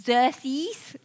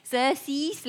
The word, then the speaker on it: Xerxes: Xerxes, okay? Xerxes, let me say it like that.